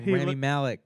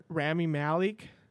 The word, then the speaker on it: Rami Malek: Right now, and let me fucking rail on Rami Malek.